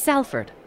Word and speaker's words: Salford: It's Salford. I don't care what you say. It is Salford. That is how it is spelled. But everyone's like, no, Salford.